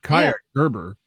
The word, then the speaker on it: Kaia Gerber: Am I saying her name right? – Yeah. – She's the daughter of Cindy Crawford. Kaia Gerber.